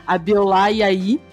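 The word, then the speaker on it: Abiola Yayi: Bom, eu tenho o maior prazer de apresentar o meu convidado de hoje, a Biolá Iaí. Não sei se falei certo, vamos, vamos é, ver. É isso mesmo. falei certo? Sim.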